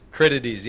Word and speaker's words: Critides: Critides, yeah, it's Greek.